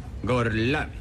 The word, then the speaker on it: Gorlomi: Gorlomi? Lo pronuncio correttamente? – Sì, è corretto. – Gorlomi? Per cortesia, me lo ripeti ancora? – Gorlomi.